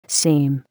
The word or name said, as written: seam